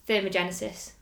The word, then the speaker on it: thermogenesis: Thermogenesis.